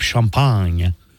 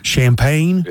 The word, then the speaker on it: Champagne: Well, I say it how Americans say it, sir. – Champagne? – Yes, sir. And you took 4 years of French. – François-Philippe Champagne.